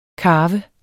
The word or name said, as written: karve